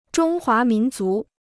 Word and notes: 中华民族: zhōng huá mín zú

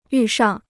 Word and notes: 遇上: yù shàng

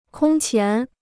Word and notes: 空前: kōng qián

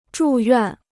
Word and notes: 住院: zhù yuàn

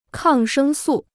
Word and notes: 抗生素: kàng shēng sù

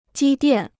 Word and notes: 积淀: jī diàn

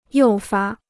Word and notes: 诱发: yòu fā

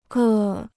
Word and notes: 各: gè

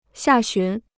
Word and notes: 下旬: xià xún

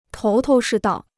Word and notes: 头头是道: tóu tóu shì dào

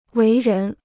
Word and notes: 为人: wéi rén

